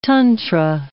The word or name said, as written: tantra